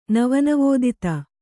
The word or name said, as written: ನವನವೋದಿತ